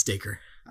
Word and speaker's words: Dacre: I didn't know how to say his name. – Yeah, it's Dacre.